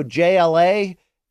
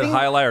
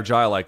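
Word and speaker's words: jai alai: For you to look at that and go JLA. – I didn't say that. I said Hialai or Jialai because someone else told me, like, after I said it was Hialai, they corrected me and told me it was Jialai.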